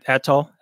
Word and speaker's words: atoll: But, I mean, one thing, the atoll, is that how you pronounce it? – Yeah, atoll.